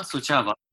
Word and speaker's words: Suceava: Ja, über die Region bei, ich weiß nicht, wie die Stadt ausgesprochen wird, Suceava oder Suceava? – Suceava. – Ja, Suceava.